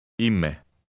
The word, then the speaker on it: είμαι: The combination of the Greek E and I at the beginning produces a long E sound, as in the word meat. Read number 4 again. – Ime.